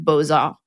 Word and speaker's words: beaux-arts: like in the term beaux-arts, which normally you wouldn't pronounce the X at the end of beau, but it's attached to the arts afterwards.